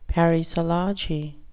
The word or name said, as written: parisology